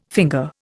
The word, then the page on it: finger: Indian